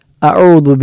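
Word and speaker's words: അഊദു (أعوذ): ഒന്ന്, അഊദു എന്നിടത്തെ ഐനും ദാലുമാണ്. അഊ എന്ന ഐന് തൊണ്ടയുടെ മധ്യഭാഗത്ത് നിന്നാണ്, ആദ്യത്തെ ഹംസ തൊണ്ടയുടെ താഴ്ഭാഗത്ത് നിന്നാണ്. ദു എന്നത് നാവ് പല്ലിൻ്റെ അഗ്രത്തിൽ സ്പർശിച്ചു സ്പർശിച്ചില്ല എന്ന അവസ്ഥയിൽ നമ്മൾ ഉച്ചരിക്കേണ്ടതായ അക്ഷരമാണ് അഊദു.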